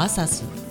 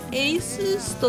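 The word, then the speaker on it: ASUS: エイスース。 – 自作パソコンの世界では有名な台湾のメーカーでね、以前はアサス、アスースなんて呼ばれてたんだよな。でメーカーがさ、正式にエイスースと読むって言ったのがつい最近。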